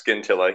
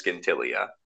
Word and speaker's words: Scintilla: He does definitely mispronounce the name of your homeworld. It is pronounced Scintilla. He says Scintilia.